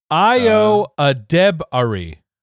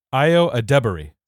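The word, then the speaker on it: Ayo Edebiri: Ayo Adebari. Ayo Adebari. – Say it fast. – Ayo Adebari.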